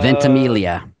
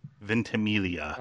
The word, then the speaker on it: Ventimiglia: For Glenn's sake, could you pronounce Milo's last name again? Because we butchered it horribly on the podcast. – Ventimiglia. – Ventimiglia. – Okay. Ventimiglia.